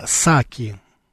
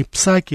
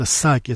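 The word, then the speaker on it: Psaki: Вот Акасамара Псаки. Э, кстати, теперь... Я, я, кстати, хочу вам напомнить, ну, просто с точки зрения лингвистики, правильно назвать Джейн Саки. Не Псаки, это Саки, это Пи не читается.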